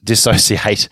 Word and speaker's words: dissociate: dis- disassociate. No, dis- dissociate.